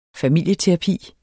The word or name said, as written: familieterapi